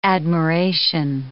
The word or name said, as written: admiration